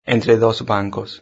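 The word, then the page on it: entre dos bancos: Spanish (Spain)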